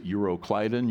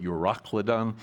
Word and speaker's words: Euroclydon: You can pronounce it Euroclydon. You can pronounce it Eurocladon.